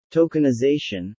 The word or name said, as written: tokenization